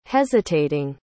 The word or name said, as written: hesitating